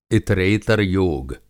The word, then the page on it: इतरेतर-योग: Hindi